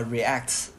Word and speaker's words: React: 那那 Vue 和 React。